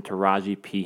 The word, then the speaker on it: Taraji P.: I like Taraji P. – Dude, someone who has to have the P pronounced in Taraji P. Henson.